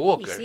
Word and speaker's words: Walker: Por eso, Walker. – Y sí, pero saque la L. – Con L. – Sí. Claro, pero sáquela al pronunciar. – Es como walkie-talkie. Walkie-talkie.